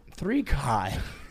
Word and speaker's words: 3Chi: Wait, that's how it's pronounced? I thought it was 3Chi. – 3Chi.